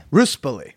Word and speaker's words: Ruspoli: Ruspoli.